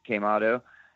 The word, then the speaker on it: Quemado: We have a new 7-inch out called Quemado, pronounced Quemado. And it's three songs on Anyway Records.